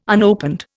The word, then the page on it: unopened: Indian